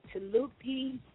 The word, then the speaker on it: Tooloopeas: and enjoying the night's events with Tooloopeas Southern Cuisine.